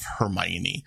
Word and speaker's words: Hermione: It's Hermione.